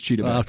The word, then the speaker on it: Chidobe: You say that, but the no pronunciation guide says Chidobe.